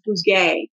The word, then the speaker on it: Bouguet: It's spelled differently than it looks. And I've met him a couple times and had to ask him every time. It's Bouguet. Chris Bouguet.